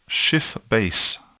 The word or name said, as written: Schiff base